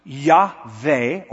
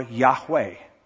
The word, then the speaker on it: Yahweh: But the overwhelming opinion of the scholars is that the name of God was originally pronounced Yahweh or Yahweh.